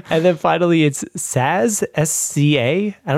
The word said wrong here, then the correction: SZA: Oh, SZA.